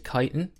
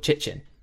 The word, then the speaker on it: chitin: And so Louise did a video on painting Tyranid Chitin, which I always called Chichin. So I learned two things. I learned how to paint that and how the word is pronounced.